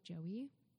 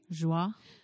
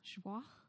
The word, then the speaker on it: Joie: But full disclosure, I used to just call it Joey. – Oh. Joie. – Joie.